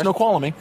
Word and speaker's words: Snoqualmie: Snoqualmie. That's the correct pronunciation.